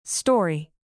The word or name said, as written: story